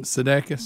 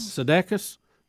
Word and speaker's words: Sudeikis: Sudeikis. – Sudeikis, better known as Ted Sudeikis.